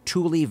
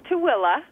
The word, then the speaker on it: Tooele: But tonight we're going to focus just, am I pronouncing it correctly? Tule Valley? – It's Tooele. – Tooele. – Everybody pronounced it Tule. That's what it's spelled.